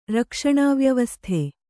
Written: ರಕ್ಷಣಾವ್ಯವಸ್ಥೆ

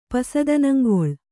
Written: ಪಸದನಂಗೊಳ್

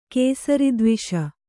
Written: ಕೇಸರಿದ್ವಿಷ

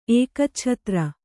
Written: ಏಕಚ್ಛತ್ರಿ

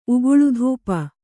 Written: ಉಗುಳುಧೂಪ

